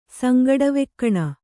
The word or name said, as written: ಸಂಗಡವೆಕ್ಕಣ